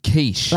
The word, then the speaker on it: Quiche: Quiche. Like the actual food.